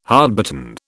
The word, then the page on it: hardbitten: British English